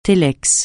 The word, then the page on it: télex: francais